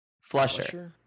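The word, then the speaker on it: Fleischer: Zombieland director Ruben Fisher has taken the helm. Did I pronounce that right? Flesher.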